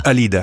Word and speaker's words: Alida: La prima cosa, io ogni volta non riesco a tenere in mente dove va l'accento, Alida o Alida? – Alida.